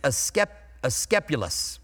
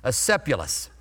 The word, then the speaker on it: Asclepius: And I always pronounce it wrong, so I'm going to try to pronounce it right today. It's Asclepius. I worked through it. Will I say it right again? Probably not. Asclepius. I'm getting there.